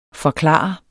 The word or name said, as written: forklare